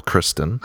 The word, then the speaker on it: Christon: And it's pronounced like a girl, Christon.